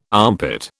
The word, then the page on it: armpit: British English